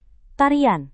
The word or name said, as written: tarian